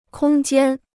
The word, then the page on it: 空间: kōng jiān